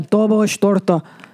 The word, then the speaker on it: dobos torta: They say, of course, dobos tort, which is not how you fucking pronounce that. Okay. She's making a dobos torta.